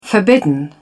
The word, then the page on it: forbidden: British English